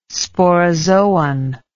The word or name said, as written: sporozoan